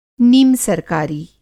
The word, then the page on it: नीमसरकारी: Hindi